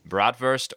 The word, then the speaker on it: Bratwurst: Bratwurst or hot dogs? I'm using the German pronunciation, of oh, course.